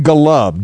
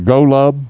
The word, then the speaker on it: Golub: Aaron Golub, G-O-L-U-B, or Golub, Golub. – Blind Lemon Golub. – Yep. – Yep. Now he's down in Louisiana. He's got to get himself a blues name. Blind Lemon Golub is what I'm going with.